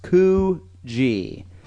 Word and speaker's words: Coogee: It's pronounced Coogee. – We were saying Coogee. – I think I was saying Coogee. So it's Coogee, the Coogee Bay Hotel.